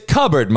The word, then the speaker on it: cupboard: It's cupboard, motherfucker. We don't pronounce the P. We don't fucking, the P is silent. Don't fucking say it.